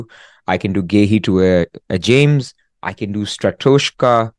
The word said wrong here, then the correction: Strakosha: I always get his name wrong. Strakosha.